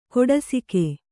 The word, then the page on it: ಕೊಡಸಿಗೆ: koḍasige